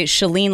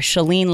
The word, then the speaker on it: Chalene: I would say Chalene like shut the door, or Chalene like Celine Dion, except I don't have her voice.